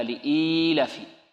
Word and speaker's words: لإيلاف: بل لابد من مد الكسرة التي على الهمزة وإشباعها حتى يتولد منها ياء، هكذا لإيلافي،